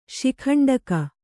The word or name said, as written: ಶಿಖಂಡಕ